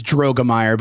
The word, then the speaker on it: Droegemeier: No, it's Drogemeyer, but it's okay.